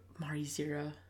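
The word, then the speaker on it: Mari0: There's also something called like Mario, but the O is spelled with the Mari Zero. – Would not, is that the way they call it? – Yep.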